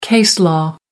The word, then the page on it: case law: American English